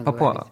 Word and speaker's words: Папуа: Папуа, правильно Папуа. – Говорить. – Ну да, по-английски немножко другое ударение. Так что как ни скажете, все будет верно.